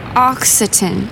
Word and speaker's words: Occitan: Occitan.